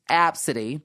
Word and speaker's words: Abcde: Her name is spelled ABCDE, and it's pronounced Apsody.